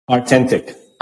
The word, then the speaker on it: Artentik: Artentik.